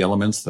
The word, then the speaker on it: LMNT: After researching many electrolyte products, only one had the elements that I've just described. Hydrate efficiently and daily as I do with a product called Element. It's spelled L-M-N-T.